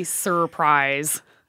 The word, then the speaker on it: surprise: Because we don't say surprise. That sounds like a knight. – That was exactly the student's point, was if we don't say that first R, perhaps we should just get rid of that first R and spell it S-U-P-R-I-S-E.